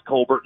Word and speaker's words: Colbert: It's pronounced Colbert. Nobody in my family for generations ever said Colbert.